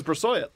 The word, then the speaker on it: Brossoit: Yeah, Brossoit and Brassoyet, if you're one of those people who pronounce Detroit in an annoying way. So, yeah, he had Brassoyet behind him, and I asked the fucking guy, and he said his name is Brassoyet.